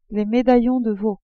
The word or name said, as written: medaillons de veau